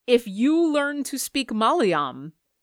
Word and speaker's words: Malayalam: If you learn to speak Malayalam.